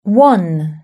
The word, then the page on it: one: British English